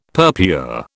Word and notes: purpure: British English

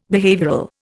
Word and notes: behavioural: Indian

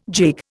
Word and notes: jig: Indian

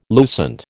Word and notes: lucent: American English

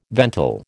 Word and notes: ventil: American English

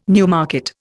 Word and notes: Newmarket: Indian

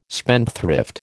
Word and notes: spendthrift: American English

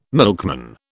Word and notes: milkman: British English

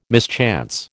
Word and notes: mischance: American English

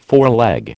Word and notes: foreleg: American English

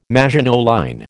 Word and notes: Maginot line: American English

